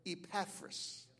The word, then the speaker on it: Epaphras: Epaphras.